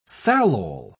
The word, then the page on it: salol: British English